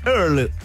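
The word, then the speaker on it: Earle: Eh, así es, eh, con Silvia Earl Sí, hemos decidido no pronunciar la última E. perfecto. Silvia Earl, oye, así que vamos a hacer una actualización.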